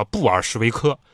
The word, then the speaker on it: 布尔什维克: 这个俄国社会党多数派呢，那个多数俄语发音叫布尔什维克。